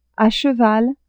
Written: a cheval

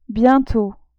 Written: bientot

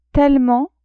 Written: tellement

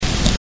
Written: Cuba

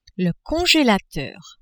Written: congelateur

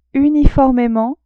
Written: uniformement

